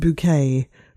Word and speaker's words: Bucket: I'm thinking of Hyacinth Bucket, pronounced bouquet from Keeping Up Appearances, or even Ricky Gervais in The Office.